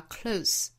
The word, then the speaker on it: close: Only if the sky and the earth the 遇到后面的元音了，由变成 e， the earth 还有后面的 close 这个词，那它作为动词的时候呢，尾部发音是 z， close，比如说关上门就是 close the door，那它作为形容词的时候发 close，就是尾部是轻辅音的 s，这个时候这个词表示亲近的 close。